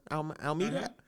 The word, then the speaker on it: Almeida: I forget how to pronounce it over Thomas Almeida. – Almeida.